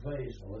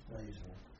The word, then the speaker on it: Wesel: Well, up to the river, when we got to the river, I think they pronounce it Wesel as if the W is a V. I think it's Wesel, the Germans pronounce it.